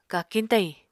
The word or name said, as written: kakiñ-tayiñ